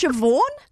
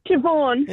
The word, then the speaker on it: Siobhan: It's S-I-O-B-H-A-N. – Oh, isn't it pronounced Siobhan? – Siobhan. – Yeah, it's just Siobhan. Yeah. – Why would you go out there and spell it S-I-O-B-H-A-N? – Yeah.